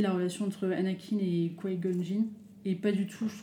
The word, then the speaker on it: Qui-Gon Jinn: Tu prononces comment tu dis Qui Gon Qui qui-Gon Jinn. ah, c'est Qui-Gon Jinn, ouais, si, si, ouais, c'est bien, je, Et ce que j'allais dire, dans la menace fantôme, au final, c'est vrai que c'est très centré bas sur.